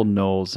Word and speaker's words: Knowles: It's just Michael Knowles.